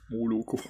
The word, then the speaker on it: Moloko: Und ähm, ja, im Russischen ist es so, dass wenn die erste Silbe nicht betont ist, oder wenn die erste Silbe betont ist, dann wird ein O als A ausgesprochen. Also geschrieben schreibt man Milch auf Russisch Moloko, man spricht Mauerko.